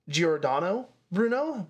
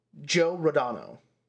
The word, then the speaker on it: Giordano: because it should be Giordano Bruno, but they pronounce it Joe Rodano is how they show it.